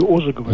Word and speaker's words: Ожегова: Значит, я недавно в словаре Ожогова или Ожегова. – Ожегова.